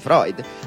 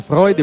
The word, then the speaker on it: Freud: Freud. Ma cosa possiamo dire di più sulle relazioni fraterne? – Innanzitutto, non si dice Freud ma si dice Freud.